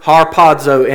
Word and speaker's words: harpazo: Greek word harpazo, and it is spelled like it sounds with a Z there, harpazo in the Greek.